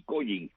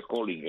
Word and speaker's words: Collins: Inclusive al machete se le llegó a llamar así Collins, como se escribe, ¿no? Aunque realmente la pronunciación sería Collins en inglés, porque se producía en Collinsville, Connecticut, acá en Estados Unidos.